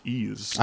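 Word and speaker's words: Ys: It took me a long time just to figure out that it was pronounced Ease.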